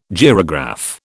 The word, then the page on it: gyrograph: British English